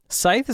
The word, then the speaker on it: Scythe: How would you pronounce this artist's name? – Scythe. – Scythe is kind of what I was thinking.